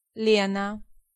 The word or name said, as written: Lena